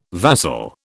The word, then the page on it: vassal: British English